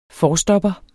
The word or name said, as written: forstopper